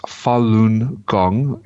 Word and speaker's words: Falun Gong: And it's pronounced Falun Gong.